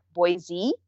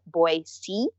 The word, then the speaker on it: Boise: And by the way, I don't know if people notice how I say it, but Boise, everyone says, if you're not from here, Boise. – Yeah. – But the correct way to say it is Boise, like S-E-E. So that took a lot of getting used to. – S-E-E, not Z-E-E.